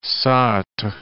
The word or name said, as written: Sartre